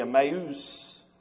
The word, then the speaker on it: Emmaus: We think it was the little town northwest, in the Greek pronounced just like we say, Emmaus.